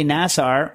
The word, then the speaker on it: Nassar: Nassar, by the way, who said thank you, rhymes with NASCAR.